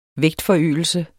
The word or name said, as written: vægtforøgelse